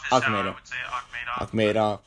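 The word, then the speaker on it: Akhmedov: Akhmadov. That's my understanding of how to say it.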